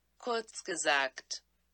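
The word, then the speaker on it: Kurzgesagt: kurz gesagt there you go oh it's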